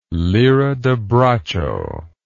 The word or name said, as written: lira da braccio